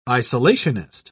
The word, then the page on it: isolationist: British English